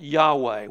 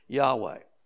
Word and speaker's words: Yahweh: And it comes out, and we think it's pronounced Yahweh. We may be wrong about that, but it's close to the word Yahweh.